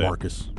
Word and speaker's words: San Marcos: Hey, by the way, you don't need the hard O on San Marcos, by the way. – How do you say Marcus? – Marcus?